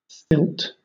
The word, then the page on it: stilt: British English